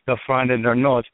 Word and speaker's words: Der Freund in der Nord: I'm going to be saying with Pennsylvania Dutch pronunciation, Der Freund in der Nord, or Der Freund in der Nord.